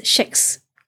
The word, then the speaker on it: Kex: It's actually pronounced as Shex, even though it's spelled K E X.